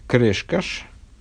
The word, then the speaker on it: Крешкаш: А, Раби Хаздай, сын Раби Аврома Крешкаш – вот как, оказывается, это произносится, а я огласованное, в огласованной форме не увидел почему-то в интернете, а, Раби Хаздай Крешкаш.